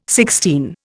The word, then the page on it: sixteen: Indian